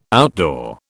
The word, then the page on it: outdoor: British English